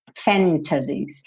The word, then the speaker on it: fantasies: What if all these fantasies, não tem problema nenhum se você pronunciar o T. Fantasies. Mas é comum, muito comum, especialmente no inglês americano, que esse T suma e aí você tem fantasies, tá?